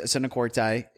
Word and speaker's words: arsenikoitai: And you brought up those two words, arsenikoitai, and I just butchered how to say that. – You got it. Arsenikoitai and malakoi. – It is koitai though, right? – Yeah.